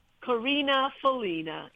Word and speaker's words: Karina Felina: Please use the English pronunciation. It's fine. I'm not precious. It's Karina Felina.